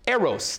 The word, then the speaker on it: eros: So the first one we have is eros, E-R-O-S, E-R-O-S, pronounced eros, right?